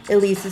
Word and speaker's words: Ilise: Elise is spelled I-L-I-S-E.